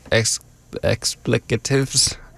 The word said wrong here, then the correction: expletives: Expletives, yeah. – Expletives.